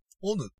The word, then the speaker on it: 隠: で、この中に鬼の語源が出てくるんですけど、オヌっていう、